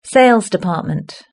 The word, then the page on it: sales department: British English